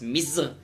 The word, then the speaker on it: Misr: Auf Arabisch heißt Ägypten Misr. Da haben wir im Prinzip die Entsprechung dieser Konsonanten MZR dort als Misr ausgesprochen.